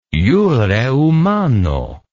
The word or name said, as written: jure humano